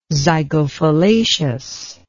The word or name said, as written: zygophyllaceous